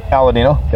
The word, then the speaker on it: Aladino: How did you pronounce that? The Aladino? – Aladino, yeah.